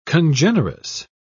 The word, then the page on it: congenerous: British English